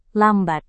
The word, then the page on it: lambat: indonesian